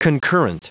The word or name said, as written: concurrent